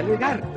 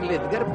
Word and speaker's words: Ledgar: Pronuncia Ledar, pero es francés y debe ser Ledgar porque es en inglés. Si Ledgar me llamo, ¿por qué me lo cambian?